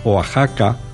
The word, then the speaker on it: Oaxaca: nosotros también podemos escribir estas palabras con X se pronuncia, sin embargo, J, como la he pronunciado yo ahora. Nunca está permitida la pronunciación México o Axaca o Texas. Los mexicanos tampoco la hacen, aunque figure la letra X.